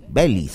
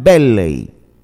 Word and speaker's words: Belley: San Antelmo de Belley, o Belly, Belly sería. Claro, así creo que sería la pronunciación. Belley.